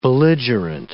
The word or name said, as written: belligerent